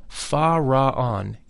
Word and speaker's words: Fa Ra An: The whole mantram, Fa Ra An, is divided into three syllables, thus, Fa Ra An. The Fa is that of the musical scale. The Ra is a deep sound. It should be vocalized with a rolling R, Ra. An